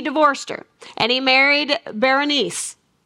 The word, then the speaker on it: Berenice: Ptolemy decides he is going to give his daughter, I call her Bernice, but it's not really spelled like that. It's like Berenice, okay? But I'm going to say Bernice, okay?